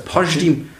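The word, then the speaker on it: Pajtim: Pasch, Pasch, wie heißt, wie spricht man das? Pashtim. Pashtim. Pashtim Kasami.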